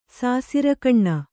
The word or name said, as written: ಸಾಸಿರ್ಗಣ್ಣ